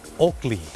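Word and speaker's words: Oakley: คนไทยออกเสียงว่าโอเกลแต่เจ้าของภาษาไม่ได้ออกเสียงว่าโอเกลครับเขาออกเสียงว่าโอกลี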